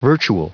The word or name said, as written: virtual